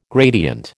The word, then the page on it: gradient: American English